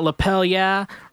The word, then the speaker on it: Lapel Yeah: First one up, we got Lapel Yeah. Rhymes with hell yeah, you know, like give me a lapel yeah.